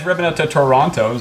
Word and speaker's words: Toronto: You even said it right. You didn't then say the other T. – Mm. – How do you say Toronto? – Toronto.